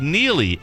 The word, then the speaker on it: Neagley: Just after Reacher finished, now they've dropped this new series that's a spinoff called Neely, NEAGLEY. The G is silent.